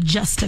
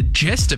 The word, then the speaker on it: gist: What I would like to know is it the gist of it or the just of it? – I think it's the gist of it, isn't it? – Like, how do you spell it? J-I-S-T? – Yeah.